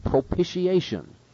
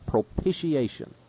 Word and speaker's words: propitiation: Now the second concept here is that of propitiation. And notice the pronunciation. Some have difficulty with this. Propitiation, as though it were P-I-S-H, propitiation.